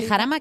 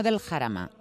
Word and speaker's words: Jarama: De Jarama, que no del Jarama. – Talamanca de Jarama, exactamente. – De Jarama, que mucha gente dice del Jarama.